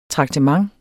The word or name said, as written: TRAKTEMENTETS